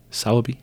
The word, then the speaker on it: Sowerby: Sowerby. – Sowerby. – Yeah. – Sowerby? – Yeah. – Sowerby. It's because you don't pronounce the R.